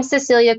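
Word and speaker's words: Cecelia: So I'm Cecilia Creates. So my first name, it's C-E-C-E-L-I-A.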